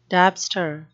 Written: dabster

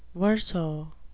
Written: verso

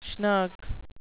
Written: schnook

